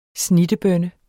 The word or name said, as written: snittebønne